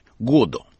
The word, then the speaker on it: Годо: Даже имя Годо, как мы привыкли произносить, у него звучит так, как произносил сам Беккет с ударением на первом слоге – Годо.